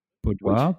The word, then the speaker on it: boudoir: we all know like boudoir photos, Is that how you pronounce it? Boudoir.